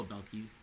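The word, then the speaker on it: Belcalis: Oh, Belkis. That's how you say her name? – Ah, Belkis. Thank you.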